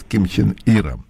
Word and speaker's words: Ким Чен Ир: пришло сообщение, вот мы сейчас в эфире, что с Jerusalem Post, что есть информация о том, что господин, как правильно произносить? – Ким Чен Ир.